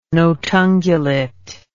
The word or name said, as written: notungulate